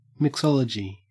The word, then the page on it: mixology: Australian English